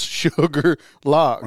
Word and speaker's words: Shuqualak: It's spelled S-H-U-Q-U-A-L-A-K. Now, where Sugarloaf come from, I don't know. – I don't know exactly either. – Yeah, but yeah. If you look it up, if you look it up on your maps on your phone, under there it says pronounce Sugarloaf.